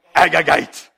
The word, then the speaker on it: Agagite: Okay, you have to say it like that. Agagite. Say it with me.